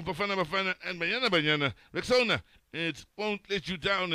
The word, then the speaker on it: Rexona: A friend called it the Corner, but guys, it's Rexona, like Rexona.